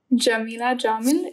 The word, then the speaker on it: Jamila Jamil: Is it Jamila Jamil? Is that how we pronounce her name? Yeah. Oh, for sure.